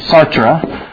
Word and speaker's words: Sartre: Now, the two of them who are most often quoted, Jean-Paul Sartre, it's S-A-R-T-R-E, Sartre, or I've heard it pronounced Sartre as well.